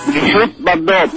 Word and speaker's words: Brigitte Bardot: Brigitte Bardot.